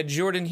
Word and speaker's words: Jorphdan: Jordan here. The PH is silent.